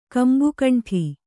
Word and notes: ಕಂಬುಕಂಠ: kambukaṇṭha

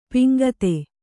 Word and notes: ಪಾಂಕ್ತ: Kannada

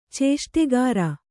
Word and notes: ಚೇಷ್ಟೆಗಾರ: cēṣṭgāra

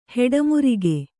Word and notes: ಹೆಡಮುರಿಕೆ: kannada

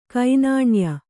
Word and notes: ಕೆನ್ನಾಯಿ: kennāyi

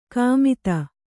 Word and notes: ಕಾಮಿತ: kannada

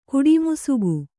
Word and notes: ಕಡುಮಿಸುಗು: Kannada

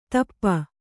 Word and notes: ಧೂಪ: Kannada

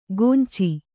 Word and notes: ಕೊಂಚಿ: Kannada

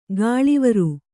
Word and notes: ಕಳೇವರ: kaḷēvara

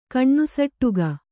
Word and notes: ಕಣ್ಣುಸಟ್ಟುಗ: kaṇṇusaṭṭuga

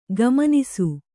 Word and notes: ಗುಮಾನಿಸು: gumānisu

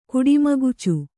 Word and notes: ಕುಡಿಮಗುಚು: kuḍimagucu

